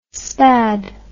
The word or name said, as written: spad